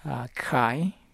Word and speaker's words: Khai: And would you say his name again? – Khai. – The Vietnamese emissary. What was his name? – His name is Khai, Phan Van Khai. – Phan? – Van Khai. – Phan Van Khai. – Yes. – C-A-I? – Mm-hmm.